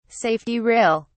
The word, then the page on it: safety rail: British English